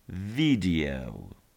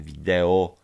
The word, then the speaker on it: video: U samej góry mamy jeszcze resztę tych słówek z lekcji 5.2 i mamy tam słówko klip wideo, które po angielsku wymawiamy jako video. Video. Video. Nie mówimy wideo, tylko video.